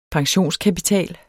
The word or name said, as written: pensionskapital